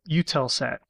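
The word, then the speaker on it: Eutelsat: And it's a small thing, but I, I I think it's pronounced uh, UTELSAT.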